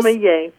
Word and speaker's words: sommelier: a certified sommelier? Is that even close? – Sommelier.